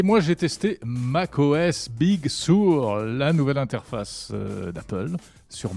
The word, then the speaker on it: Sur: Comment Big Sur, tu m'énerves. – Sur Oui, Sur. Ah. – non, mais ça s'écrit S-U-R. Ah, d'accord, c'est pas sourd, d'accord.